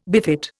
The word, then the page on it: befit: Indian